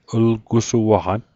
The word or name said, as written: llgusuaqan